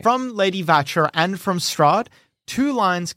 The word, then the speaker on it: Watcher: The name written beneath this is Lady Vatcher with a W. Vatcher, but with a W. – Lady Vatcher, but spelt Watcher? – Yeah, spelt Watcher, but with a, it would be pronounced Vatcher.